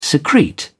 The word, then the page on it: secrete: British English